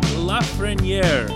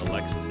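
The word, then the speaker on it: Lafrenière: I'll save you the trouble of looking it up, or for that matter, looking up the correct pronunciation. That's actually how you say it. First name looks like Alexis. It's pronounced Alexis. The last name is Lafreniere.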